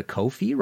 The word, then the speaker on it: Ko-fi: If you like the show and want to support future episodes, please go to Ko-fi, rhymes with no fee, .com/MartinPopoff.